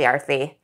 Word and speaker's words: Arthi: And I was like, actually, Arthi, I called myself Artie back then. I didn't know how to pronounce my own name.